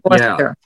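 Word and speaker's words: Forster: It's F-O-R-S-T-E-R. – Yeah, and that's it. Foster.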